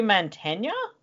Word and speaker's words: Mantegna: He also wrote the screenplay with Michael McDowell, and the film stars Robert John Burke, Lucinda Jenny, Bethany Joy Lenz, Joe Montana. I always get his name wrong. Joe Mantegna? – Yep, that's sure.